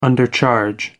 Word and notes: undercharge: American English

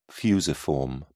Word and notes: fusiform: British English

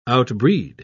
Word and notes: outbreed: British English

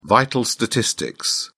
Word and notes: vital statistics: British English

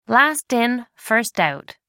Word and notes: last in first out: American English

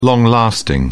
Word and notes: long-lasting: British English

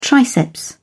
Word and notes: triceps: British English

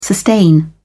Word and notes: sustain: British English

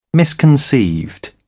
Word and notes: misconceived: British English

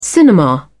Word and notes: cinema: British English

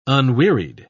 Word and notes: unwearied: British English

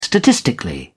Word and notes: statistically: British English

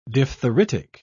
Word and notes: diphtheritic: British English

